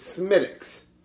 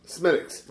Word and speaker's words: Smithwick's: You do not pronounce it like you read it. It's Smittick's. – Oh, Smittick's. – Smittick's. – Like Smitty. I got it.